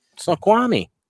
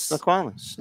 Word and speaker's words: Snoqualmie: Snoqualmie. – Is that really how it's pronounced? Yes. – Snoqualmie.